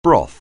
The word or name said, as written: broth